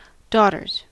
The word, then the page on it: daughters: American English